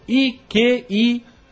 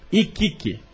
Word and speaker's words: Iquique: Iquique. Iquique.